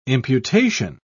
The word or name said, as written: imputation